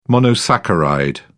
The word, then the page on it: monosaccharide: British English